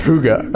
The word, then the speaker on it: Hygge: That's really, it's pronounced Hygge. – Hygge? – Hygge.